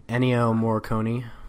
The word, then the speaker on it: Ennio Morricone: Ennio Morricone. – Ennio Morricone, that you know, his music's like ghetto or something.